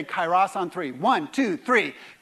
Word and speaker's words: kairos: To say it properly, you really have to get the guttural kairos.